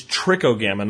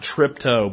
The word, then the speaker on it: Trichogramma: I believe it's Trichogramma, not Tripto, but Tricho. If you want to look them up online, it's Trichogramma, Trichogramma.